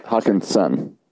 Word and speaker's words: Hockenson: So they go into week 3 and 4 with a couple tough tight ends showing up the next couple weeks with Hockenstein and Fant. Hockenstein, that's right, isn't it? Hockenstein? – Yeah. Hockenson. – Hockenson. I knew I was saying it wrong.